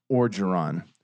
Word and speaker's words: Orgeron: It is fucking Orgeron. – Yeah. – O-R-G-E-R-O-N. O-R-G. Okay.